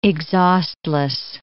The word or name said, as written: exhaustless